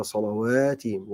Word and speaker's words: صلواتهم: هتلاقي الواو الألف مش فوقها مباشرة الألف بعديها فتنطق الواو تقول على صلواتهم واو فتحة صلواتهم نطقت الألف تمام